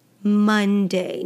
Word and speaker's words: Monday: And we say Monday, Monday. Now the O is actually pronounced more like a U, a Monday, Monday.